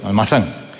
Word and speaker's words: Almazán: Sí, yo sí lo he pronunciado bien, Almazán.